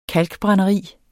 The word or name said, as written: kalkbrænderi